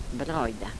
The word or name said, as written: brajda